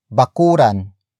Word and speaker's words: bakuran: Word for word it's bakuran, ang, palibot, ng, bukid, bakuran, ang, palibot, ng, bukid, bakuran, ang, palibot, ng, bukid.